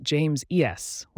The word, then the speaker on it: James's: If you write James's, be sure to pronounce it James ES when you read aloud to correctly convey that possessive.